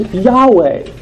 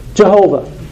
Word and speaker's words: Yahweh: Let's notice that the word that is used of the Lord here in our passage is Yahweh, Jehovah, some people would pronounce.